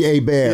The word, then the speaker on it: Hebert: That's not how you pronounce it. – For me, it does. – Bobby Hebert. Yeah. He's French.